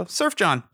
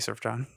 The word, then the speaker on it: Sufjan: The Sufjan Stevens tour, because he knows that people will mispronounce his name. So, Sufjan. – Sufjan. – Yeah, it was... As I was saying his name the first time, I'm like, please don't say Surf John.